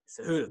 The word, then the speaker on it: Cejudo: Cejudo.